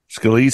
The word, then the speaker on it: Scalise: So here's some other names that are out there floating around: Tom Emmer, Steve uh, Steve Scalise, right? Is that how you say it? – Scalise. Scalise yeah.